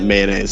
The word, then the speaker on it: Menai: No. I said mayonnaise. M-E-N-A-I. – Oh, I see. Okay. So not mayonnaise straight.